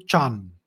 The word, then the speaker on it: chan: The Chinese don't know how to pronounce dhyan, so they turned it into chan.